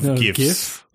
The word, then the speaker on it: GIF: Of GIFs. – Yeah. Oh, right. – It's pronounced GIF. – No, it's not. – Apparently it is. – Catch up, man.